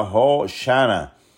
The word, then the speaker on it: Hoshana: Osana se describe o se pronuncia Hoshana,